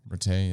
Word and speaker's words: Rattay: And I know our new quarterback coach Tim Tim Rattay is that is that my pronoun? I think I'm pronouncing his name correctly. Yeah, Rattay. Yeah, sounds right.